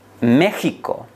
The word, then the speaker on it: México: Você já deve ter se perguntado alguma vez por que México, é escrito com X, quando pronunciado México e não México. Em espanhol, claro. No caso da palavra México e a sua derivada mexicano, é a preservação de uma ortografia arcaica.